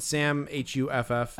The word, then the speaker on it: Huff: Just Sam, H-U-F-F. – So it's not like Charlie? It's Sam Huff? Okay. – Yeah, yeah. Yeah, like huffing, you know, rubber cement or something.